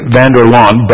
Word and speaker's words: Vanderlaan: Ray Vanderlaan, and if you have not familiar how to pronounce his last name, it's like mowing the lawn, Ray Vanderlaan, but he prefers to go by RVL, as he is affectionately known.